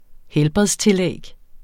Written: helbredstillæg

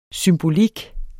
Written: symbolik

